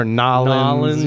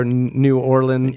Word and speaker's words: New Orleans: Is that how you say it? New Orleans? Or Nolens. You can say it. Or New Orleans. Yeah. New Orleans.